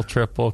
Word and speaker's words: Tripel: No, they spell it T-R-I-P-E-L, Tripel. They do. Look it up. I'm looking at it right now. – Right, but you could just pronounce that triple. – Hmm? – That's a typo. – You could pronounce that triple. You could read that triple. – You could. Triple. Tripel. Tripel. Say it really fast.